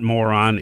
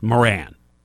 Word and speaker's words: Moran: his name is not Moron. It's actually pronounced Moran.